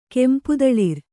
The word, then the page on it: ಕೆಂಪುದಳಿರ್: kannada